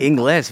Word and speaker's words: Ingles: Then you got Joe Ingles. He's really fucking good. – Ingles, very cool. Very nice pronunciation.